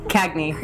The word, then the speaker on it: Cagney: Cagney.